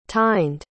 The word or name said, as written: tined